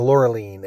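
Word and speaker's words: Laureline: But I've listened to several interviews with Luc Besson, directed the movie, and he refers to her as Loreline, and I think a couple other people have as well. So we were mispronouncing her name, I guess because we're not French.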